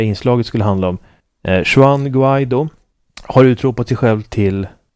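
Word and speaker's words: Guaidó: Det är lite osäkert på om man säger Guaido eller Guaido. Eller Guaido låter inte spanskt. – Jag har hört inspelningen nämligen av spansktalande människor som definitivt lägger betoningen på andra stavelsen.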